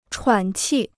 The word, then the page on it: 喘气: chuǎn qì